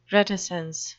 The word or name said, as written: reticence